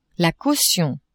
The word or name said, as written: caution